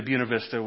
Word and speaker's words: Buena Vista: And if you've been to Buena Vista,